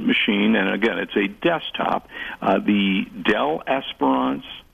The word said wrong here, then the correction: Inspiron: Dell Inspiron, a desktop AMD.